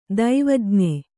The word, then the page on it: ದೈವಜ್ಞ: daivajña